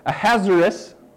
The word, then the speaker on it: Ahasuerus: Because king, is it Ahasuerus? How do I say that? Yes, Ahasuerus, okay, had declared that everybody in the land should bow down to his right-hand man named Haman.